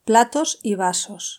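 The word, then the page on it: platos y vasos de plastico: European Spanish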